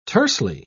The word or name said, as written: tersely